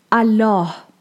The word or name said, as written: Allah